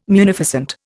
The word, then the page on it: munificent: Indian